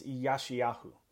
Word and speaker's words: Yeshayahu: And today's name is Isaiah, or if we were to say it correctly in Hebrew, unlike the English way, it's Yeshayahu.